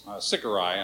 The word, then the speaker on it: sicarii: A zealot, they had these knives called sicarii, I believe the right pronunciation is, and it's like a special curved knives, and they wanted to have them in every Roman's back that they could get near.